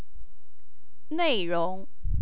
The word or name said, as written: neirong